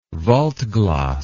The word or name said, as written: waldglas